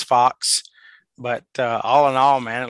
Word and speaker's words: Fuchs: it's Matt Fox, but it's spelled not exactly spelled Fox, not what the Fox say. – No, it's spelled F-U-C-H-S.